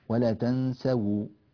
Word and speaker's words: ولا تنسوا: يعني بقيت ملاحظة جيدا أن ننبه إليها أيضا في قوله تعالى ولا تنسوا الفضل بينكم، هذه الواو مضمومة تخلصا من التقاء الساكنين، الأصل أن هذه الواو تكون ساكنة هكذا وجاء بعدها هذه اللام الساكنة هكذا.